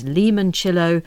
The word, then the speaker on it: limoncello: And I said limoncello, and Anna, who's Italian, says, it's limoncello, and please pronounce all the consonants in mascarpone.